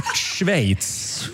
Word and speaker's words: Schweiz: Schweiz.